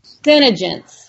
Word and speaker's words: CeneGence: CenGence? – CenGence, yes.